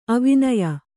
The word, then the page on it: ಅವಿನಯ: avinaya; kannada